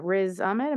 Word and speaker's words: Riz Ahmed: Riz Ahmed.